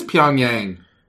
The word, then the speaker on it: Pyongyang: It is Pyongyang.